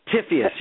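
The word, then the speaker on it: Typhoeus: Typhius. You can say Typhius. – Typhon if you want, or you can say Typhius in the Greek.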